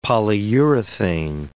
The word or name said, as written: polyurethane